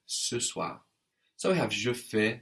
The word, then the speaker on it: je fais: I do in French is je fais. I do, je fais. – Je fais. – That's spelled F-A-I-S. Je fais. And of course, we have the AI giving us an E sound, like in maison.